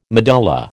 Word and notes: medulla: American English

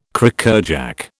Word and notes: Crackerjack: British English

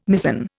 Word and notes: mizen: Indian

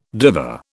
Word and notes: diva: British English

